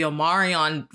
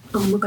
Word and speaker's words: Omicron: Omarion virus, and I was like, what is this called?